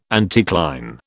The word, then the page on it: anticline: British English